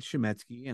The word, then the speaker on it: Chametzky: Well, I'm on social media, so it's David Chametzky, and I'll spell that last name.